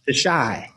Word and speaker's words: Chi: I was watching, and I don't know if the show's come back or not, a TV series called, and I don't know how to pronounce it, as the Chai or the Chi. – Oh, the Chai.